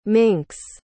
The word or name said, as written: mink's